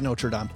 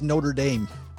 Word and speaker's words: Notre Dame: The one in Paris is Notre Dame. This is Notre Dame.